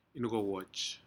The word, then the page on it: incowoj: Aneityum